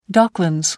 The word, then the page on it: docklands: American English